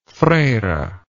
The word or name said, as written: Freyre